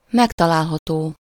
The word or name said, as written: occur